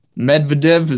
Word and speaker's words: Medvedev: Medvedev, is that how you pronounce his name? – Good job.